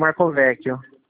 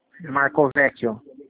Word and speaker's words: Marcovecchio: Rodrigo Irineu Marcovecchio. – Rodrigo Irineu? – Marcovecchio.